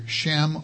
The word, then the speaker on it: Shem: In the Old Testament, the Hebrew word for name is either Shem or Sem, either S-H-E-M, sometimes it appears, the H is silent, or S-E-M.